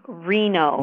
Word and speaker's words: Rienow: It's actually Rob Reno. – Reno. – R-I-E-N-O-W.